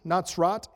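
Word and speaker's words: Natsrat (נצרת): Nazareth, the name, is pronounced Natsrat in Hebrew.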